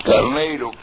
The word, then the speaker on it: Carneiro: Con White, W-H-I-T-E, Stewart, como lo hemos escrito antes, Harris, con H, Carneiro, como se escribe, como se pronuncia, Carneiro, coma, Salins, Service, coma, Salins, coma, etcétera.